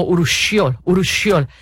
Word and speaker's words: urushiol: Se pronuncia aproximadamente así como urushiol.